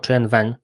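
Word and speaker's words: Qwen: Mówimy o Gwen, wymawianym jako Chen Wen, co w języku chińskim oznacza po prostu 1000 pytań.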